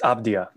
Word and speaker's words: Avdija: But, I mean, acquiring Russell Westbrook, you know, the re-signing Davis Bertans, the rookie pick Denny Avdija, is that how you pronounce that? – It's Avdija. – Avdija, okay. Denny Avdija, yeah.